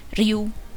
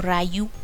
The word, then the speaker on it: Ryu: Eh, aunque el verdadero protagonista de los videojuegos es Ryu o Rayu, como le dicen los gringos y como le dicen en esta película. Rayu, por favor, señora, así no se dice.